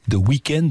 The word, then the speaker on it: The Weeknd: Alors ça s'écrit The Weeknd, sauf qu'il n'y a pas de E sur le dernier Ken. Je ne sais pas pourquoi, W-E-E-K-N-D, The Weeknd, donc Blinding Light.